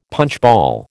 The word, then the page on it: punchball: American English